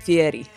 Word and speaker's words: Fieri: Guy Fieri. We're supposed to pronounce it Fieri.